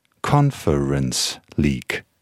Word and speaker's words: Conference League: Conference League spricht man so aus. Conference League.